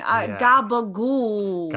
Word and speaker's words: gabagool: Mostly gabagool. – Yeah, you know, the real Italians, they cut off the last syllable. – Oh, that's interesting. Gabagool.